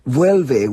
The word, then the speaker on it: Vuelve: And the song is, how do I pronounce the song? Is it Vuelve? – Vuelve, which means come back.